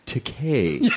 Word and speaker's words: Takei: I was like, George Takei, I mean, and he goes, no, it's pronounced Takei. It rhymes with gay. As in, it's okay to be Takei.